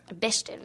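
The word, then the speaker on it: Bechdel: Et d'ailleurs, la plupart de ces films-là ne passent pas le test Bechtel, je pense, ou Bechtel, je ne suis pas sûre de la prononciation. – Bechtel, merci.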